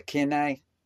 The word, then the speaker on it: kine: The kineh, K-I-N-E.